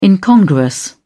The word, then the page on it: incongruous: British English